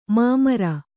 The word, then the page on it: ಮೈಮುರಿ: mai muri